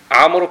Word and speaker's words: عمرو: عمرو كان يخلونها واو بالكتابة عمرو يخلونها واو عمر بدون واو